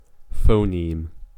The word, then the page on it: phoneme: American English